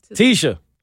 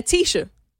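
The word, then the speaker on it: Tesha: Tisha. Yeah, Tisha. If I say it right, because it's T-E-S-H-A.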